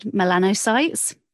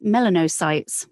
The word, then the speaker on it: melanocytes: And it's interesting, one of the projects, there was one particular word, I would have said melanocytes. when I read it, but it can also be pronounced melanocytes, which is slightly different, just the vowels are slightly different. And I wasn't aware of this, but when I did the research, I found that one was American and the other was British.